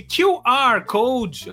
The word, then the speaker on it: QR Code: Basta você baixar o aplicativo do PicPay e apontar o leitor de QR Code. Eu aprendi a pronúncia certa esses dias. QR Code.